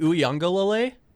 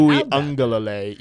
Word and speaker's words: Uiagalelei: Not even DJ Uiagalelei? – You pronounce that so good. – Thank you. – Uiagalelei.